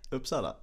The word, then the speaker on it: Uppsala: Uppsala.